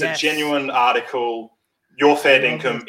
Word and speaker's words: fair dinkum: So to spell it for people, fair, F-A-I-R-D-I-N-K-U-M, right? Fair dinkum, not fair, fair dinkum. Income, fair dinkum.